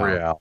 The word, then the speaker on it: Real: It's Real. – Real. – Yeah. All right, see, I already fucked it up. – Well, it's Spanish.